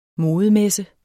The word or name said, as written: modemesse